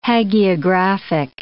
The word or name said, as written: hagiographic